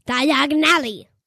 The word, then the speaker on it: Diagon Alley: No, this is how you say Diagon Alley.